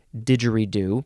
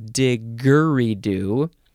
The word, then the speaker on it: didgeridoo: or you can replace the G with a J, didgeridoo, um, and it is still still still pronounced didgeridoo instead of diggeridoo.